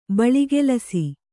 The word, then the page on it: ಬಳಿಗೆಲಸ: Kannada